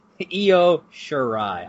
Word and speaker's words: Io Shirai: I learned how to say Shirai's last name before doing this podcast. Io Shirai. I even phonetically spelled it out in my notes so that I would say it correctly.